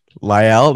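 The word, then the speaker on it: La'el: Like you said, they've vastly improved their offensive line with Latell Collins. – Lyle, that's how you say it? – Yeah, Lyle Collins.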